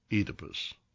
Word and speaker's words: Oedipus: The correct pronunciation is a long E, as in Phoenix, Fetus, and Phoebe. The ancient Greek state of Boeotia is pronounced Boeotia. The god of wine is Enus, and Oedipus is pronounced Oedipus.